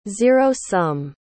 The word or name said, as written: zero-sum